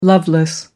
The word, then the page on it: loveless: American English